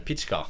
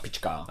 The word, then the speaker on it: Pitschka: Det är så man ska uttala det, Pitschka. – Pitschka.